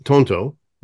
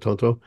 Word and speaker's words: Tonto: So the song Tonto right? That's the pronunciation of Tonto.